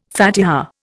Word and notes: Fatiha: Indian

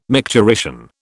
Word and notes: micturition: British English

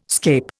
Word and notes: scape: Indian

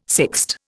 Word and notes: sixte: Indian

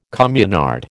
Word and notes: communard: American English

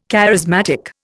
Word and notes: charismatic: Indian

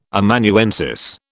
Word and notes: Amanuensis: British English

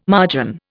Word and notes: margin: Indian

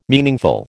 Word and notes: meaningful: American English

